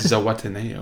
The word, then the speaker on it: Zihuatanejo: I think it's Zihuatanejo. – Zihuatanejo.